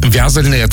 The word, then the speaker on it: вязальное: Вязальное — это у них правильно вот такое ударение, да?